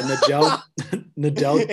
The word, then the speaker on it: Nedeljkovic: Yeah, Nedeljkovic. – It's like the two greatest men's tennis players put together, Nedeljkovic.